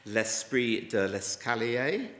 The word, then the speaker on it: L'esprit de l'escalier: But one quick one, I'll get over it because the other three make quite an interesting point, is l'esprit des... Sorry, I should be able to pronounce this much better than that. L'esprit de l'escalier,